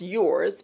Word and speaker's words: URS: Which is pronounced yours. People call it URS because it's spelled URS, but I was being tricky. My friends still say, give me some of that URS makeup. But no, it's called yours.